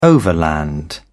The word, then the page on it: overland: British English